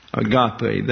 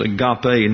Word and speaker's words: agape: A-G-A-P-E. Agape. That is an eta out here, right there. That is a long E in Greek, and it's pronounced like a long English A. So it's agape, not agape.